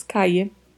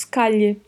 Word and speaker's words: calle: Quanto à pronúncia, a gente tem aí um duplo L. E como já falamos em outros episódios, ele pode variar. Possíveis pronúncias para essa palavra. O duplo L pode ter som de I, então falaríamos caia. Pode ter som de LH, então falaríamos calhe. Pode ter som de J, KG.